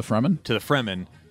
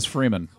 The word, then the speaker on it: Fremen: To the Fremen. – Then it got... – Don't pronounce that as Freeman, because you'll be corrected.